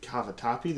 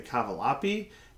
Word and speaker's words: cavatappi: Working at Noodles & Company, people don't know how to say cavatappi. They say cavalappi.